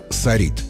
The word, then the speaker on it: сорит: Одно из них – сорит. Да, представьте себе, ударение в нем ставится на второй слог, а вовсе не на первый, как многие любят.